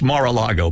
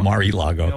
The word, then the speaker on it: Mar-a-Lago: And it's Mar-a-Lago, by the way, not Mar-e-Lago.